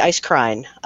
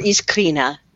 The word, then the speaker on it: Iskrina: And then Sarah Monette and I are working on the long-delayed third book in the Ice Crine, which is, or Iskrina, to give it the correct pronunciation, which I never do.